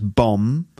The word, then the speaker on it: bomb: Let me just remind you that you don't pronounce the B. So it's bomb. Not bomb, and that's an explosive device.